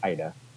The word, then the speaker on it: Aida: I've heard it pronounced so many different ways over the last year in the festival circuit, Aida and Ada and things like that. But yeah, it's Aida.